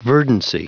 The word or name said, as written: verdancy